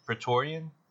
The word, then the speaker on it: Praetorian: Am I pronouncing that right? Is it Palatorian? – Praetorian? – Praetorian? – Yeah, I think.